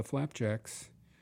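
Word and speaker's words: Flapjacques: Lovely Lo-Fi is going to mix on the air with the Flapjacks. That's pronounced Flapjacks, but it's got the French spelling of Jacques in the name.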